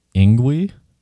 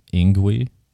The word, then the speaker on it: Ngwhi: The dragon itself was referred to as Ingwi, which is spelled N-G-W-H-I, Ingwi.